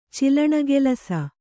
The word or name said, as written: ಚಿಲಣಗೆಲಸ